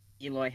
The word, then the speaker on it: Eloy: And a name slip up like Elroy, it has the name written, but then next to it on these note sheets, it's phonetically written. – Yeah, exactly. Yeah. – And he's one of the stars of the team. I feel like you should know Eloy Jimenez by now.